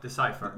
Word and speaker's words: Decipher: Detta rollspel gavs ut av ett företag som heter Decipher, eller Decipher, eller hur man uttalar det. – Decipher.